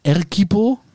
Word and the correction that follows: Arecibo: Es ist A ah, Arecibo. Spanisch.